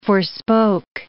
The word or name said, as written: forespoke